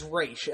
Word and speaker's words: Ra's: The name they say it's supposed to be is Ra's, and that's straight from Denny O'Neill. So I'm going to take that as the correct way of pronouncing it, but there's so many different ways, and I don't like to get in the conversation with people.